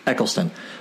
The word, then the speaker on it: Eccleston: Eccleston.